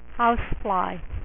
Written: Baxter Stockman

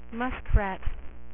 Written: Muskrat

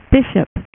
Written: bishop

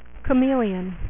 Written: Chameleon

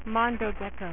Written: Mondo Gecko

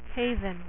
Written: Haven

